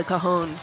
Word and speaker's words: cajon: What it's called a cajon. – Cajon. – It means big box. – And it's spelled C-A-J-O-N.